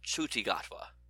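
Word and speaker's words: Ncuti Gatwa: Also, the new doctor's name is pronounced Chuti Gatwa.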